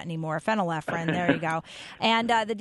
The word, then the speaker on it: phenylephrine: Phenylephrine. – Yep. – No. – Phenylephrine. – Phenylephrine.